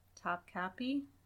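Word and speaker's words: Topkapı: How do you say it? – Topkapi. – Topkapi Palace.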